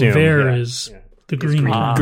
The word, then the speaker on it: haricot vert: Oh, that's how that word is pronounced. Now I know the haricot vert. – Haricot vert, right.